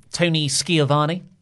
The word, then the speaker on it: Schiavone: You said his name wrong. Tony Schiavone. – Is it? – Is how Jericho says it.